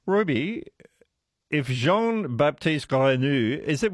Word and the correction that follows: Grenouille: was that a good pronunciation? – Is it Grenouille? I think if it's the French word, it's Grenouille. – Grenouille, thank you. Yeah. Because it is the French word, but not pronounced very well.